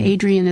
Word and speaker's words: Adrienne: And Adrian is spelled A-D-R-I-E-N-N-E.